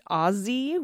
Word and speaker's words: Aussie: Did you know it's pronounced Aussie with a hard Z, like in buzz or fuzz? Like how you would pronounce Ozzy Osbourne. Americans do it with soft S like sassy or Mississippi, and that's just wrong.